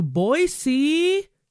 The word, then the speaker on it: Boise: We're making our way from the zone of death to Boise. See, I said it right. – Oh, you did. Oh, boy. – Boise.